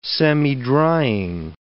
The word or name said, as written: semidrying